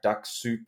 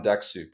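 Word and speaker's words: Dux-Soup: Now, there are a few of them called, like, ones like Duck Soup. I think that's how you say it.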